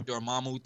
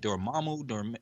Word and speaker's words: Dormammu: like am I saying it right? Dormammu? – Yeah. – Is it Dormammu? – Yeah, there you go. – Dormammu. – No, yeah, no, you got it the first time.